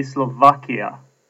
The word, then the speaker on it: Slovakia: I'm now editing this episode of the podcast, and I've just realized that I am totally mispronouncing the name of the country. It should actually be Slovakia.